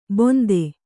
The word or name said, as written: ಬೊಂತೆ